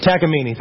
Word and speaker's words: Takamine: Actually, how do I pronounce it? Takamine. Okay, here we go. Takamine? Takamine. Thank you.